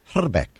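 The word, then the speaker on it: Hrbek: I speak a little bit of Czech, and the actual correct pronunciation of his name is Herbeck. You got to roll the eye.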